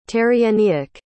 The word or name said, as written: terrienniak